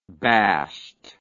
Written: bashed

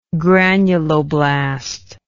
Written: granuloblast

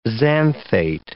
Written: xanthate

